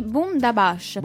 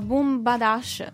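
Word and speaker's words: Boomdabash: L'ho, eh, l'ho detto bene, sono... Bomb da Bash. – Sì, Boom da Bash. – Boom penso. da Bash. – Io li chiamavo Boom Badash.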